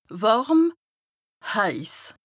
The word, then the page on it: wàrm / heiss: Bas Rhin; Strasbourg